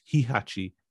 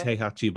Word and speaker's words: Heihachi: It's not Heihachi, it's Hihachi, Heechhatch, right? In Tekken I thought 7. it was Heihachi, actually. – So. It is Heihachi, but I just say Heechhatch and Alex hates it, so it's Heechhatch.